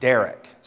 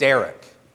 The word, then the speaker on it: derek: Now, in the Hebrew, the commonly used word for road or way or path is the word derek. Sounds like the word's name. Say it, derek. Derek.